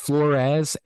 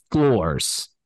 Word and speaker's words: Flores: We had heard it pronounced Flores and Floors. It has been confirmed on the official roster that was given out at practice. It is Floors, like the floors in a house, in a room.